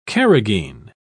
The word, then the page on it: carragheen: British English